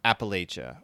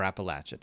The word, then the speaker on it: Appalachia: I don't know how much you know about Appalachia, or Appalachia, depending on how you want to pronounce it.